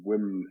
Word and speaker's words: Wim: Not a direct one, and you pronounce it Wim, by the way. He's quite particular about that. – Oh, sorry, yeah. Wim.